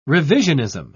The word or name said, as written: revisionism